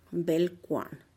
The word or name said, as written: bel-kuan